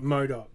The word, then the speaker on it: MODOK: MODOK, right.